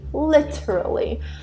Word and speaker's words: literally: Se você tiver uma pronúncia mais britânica, você pode, né, fazer aquele som do T, tipo literally, literally. I'm literally British. Só que não, but I'm not. Então, eu pronuncio literally e a Nick pronuncia literally. Então, we literally pronounce it the same way.